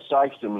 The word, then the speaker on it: Sikeston: Lynn in Sikeston, Missouri. Am I saying that right, Lynn? – Yeah, Sikeston, Missouri.